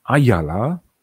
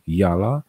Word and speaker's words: IALA: Dla tych, którzy są z morzem związani, system Ayala bo tak się wymawia to z e, angielskiego, a po polsku po prostu jala jest znany.